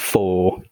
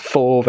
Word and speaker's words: for: And um, so, for example, the word for is not pronounced as for very often, but we pronounce it as for. – Yeah. – Or the word from is not pronounced as from, but pronounced as from. – From.